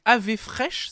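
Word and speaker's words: Daði Freyr: Ça s'écrit Daddy Frère, mais apparemment ça se prononce donc AV Fresh, c'est ça ah, D'après David.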